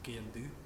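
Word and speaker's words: sgian-dubh: And it's pronounced S-G-I-A-N-D-U-B-H, I think. – Mm-hmm. – And it's just pronounced skiandu. – Skiandu. – Skiandu. It's just Gaelic. They leave out... – Why have all those extra words? – Yeah, they leave out like half the words.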